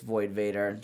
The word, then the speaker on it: Void Vator: It's all just Void Vader. – Mm-hmm. Void Vader, but V-O-I-D, the most important part.